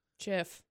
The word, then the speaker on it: Jif: The peanut butter that is spelled J-I-F is pronounced? – Jif. – Jif peanut butter.